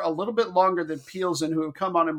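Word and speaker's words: Plzeň: Yeah, that's an L, not an I, believe it or not. They need to buy a vowel. P-L-Z-E-N. – Pilsen. – Yeah, it's actually pronounced Pilsen, it turned out.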